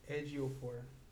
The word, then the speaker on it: Ejiofor: Ejiofor. – Ejiofor? E-J-I-O. – Do you pronounce F-O-R.